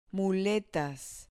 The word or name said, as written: muletas